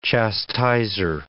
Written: chastiser

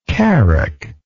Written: carack

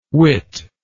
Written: witt

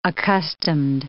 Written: accustomed

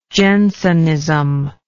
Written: Jensenism

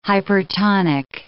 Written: hypertonic

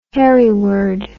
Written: hereward

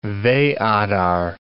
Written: Veadar

